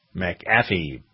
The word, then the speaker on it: McAfee: Victoria taught me how to pronounce his name. If you have a capital A, then that makes it more McAfee. – Pronounced. McAfee, apparently.